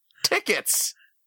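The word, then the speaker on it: Tiketz: It's T-I-K-E-T-Z. Ticket? Oh, Tickets! I just got that! Tickets! His name is Tickets!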